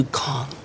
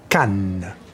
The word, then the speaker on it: Cannes: Oh, we should launch in conjunction with the Festival du Cannes. – Actually, it's pronounced Cannes.